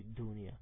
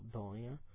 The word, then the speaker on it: Dunia: e da una parte in Africa la pronunciano con la U più pronunciata, per cui Dunia. Invece qua la pronunciano un po' più con una O, Donia, Donia, per